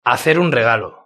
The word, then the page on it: hacer un regalo: European Spanish